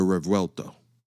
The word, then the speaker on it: Revuelto: The Revuelto. Uh-huh. Yeah, you heard that. Real Italian. Spelled R-E-V-U-E-L-T-O, Revuelto. That is now the successor to the Aventador.